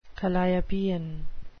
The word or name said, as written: calliopean